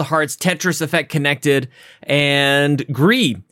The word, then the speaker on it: Gris: It's French for gray, so you don't pronounce the S, okay? So that's, you're not supposed to, everybody says Greece, but it's not like the John Travolta, Olivia Newton-John thing.